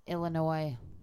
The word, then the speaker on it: Illinois: It's pronounced Illinois. – Illinois. – Not Illinois. Illinois.